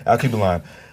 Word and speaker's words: Alkebulan: A-L-K-E-B-U-L-A-N. Akiba Line.